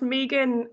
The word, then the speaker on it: Megan: I am immediately going to tell you that my name is pronounced Megan. It's Australian.